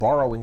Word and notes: borrowing: American English

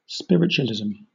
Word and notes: spiritualism: British English

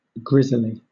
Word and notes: grizzly: British English

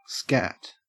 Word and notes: scat: Australian English

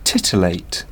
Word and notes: titillate: British English

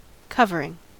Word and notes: covering: American English

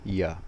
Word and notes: ya: American English

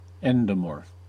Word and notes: endomorph: American English

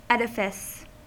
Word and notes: edifice: American English

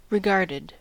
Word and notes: regarded: American English

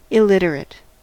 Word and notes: illiterate: American English

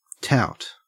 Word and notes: tout: Australian English

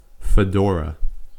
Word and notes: fedora: American English

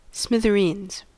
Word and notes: smithereens: American English

